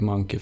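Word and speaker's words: Mynci: De ja, det uttalas monkey i alla fall.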